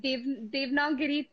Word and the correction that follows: Devanagari: Devanagari, yeah.